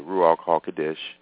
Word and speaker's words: Ruach HaKodesh: I'm reading this in the complete Jewish Bible version, and that's who set me up, folks, is the Holy Spirit, the Ruach HaKodesh. That's how you say it in Hebrew.